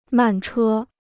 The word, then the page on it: 慢车: màn chē